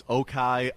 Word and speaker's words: Ochai: I know it's Okai Agbaji, O-C-H-A-I. So is that chai latte, like a chai a tea? And Agbaji, A-G-B-A-J-I, is the name.